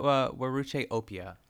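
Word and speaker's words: Weruche Opia: Am I pronouncing that correctly? Yeah, I looked it up. Waruke Opia.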